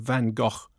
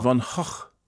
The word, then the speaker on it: Van Gogh: And congratulations to Mark on getting the pronunciation of Van Gogh absolutely spot on. – Van Gogh. – Van Gogh.